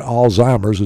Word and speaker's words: Alzheimer's: It's great for especially anybody who's stressed for whatever reason, even people that's been diagnosed with dementia or Alzheimer's is actually the correct pronunciation.